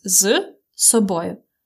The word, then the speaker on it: з собою: You see, uh, this з собою is two words. It's з собою. But when you pronounce it fast, you really hear just long s.